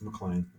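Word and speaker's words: MacLean: McLean. Some people say McLean when they pronounce McLean. It, but it's McLean. That's how I pronounce it. Yeah. M-A-C, not M-C.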